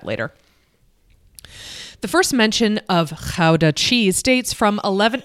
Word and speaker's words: Gouda: Gouda, or Gouda, as it's pronounced in the Netherlands.